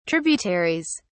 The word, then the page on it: tributaries: British English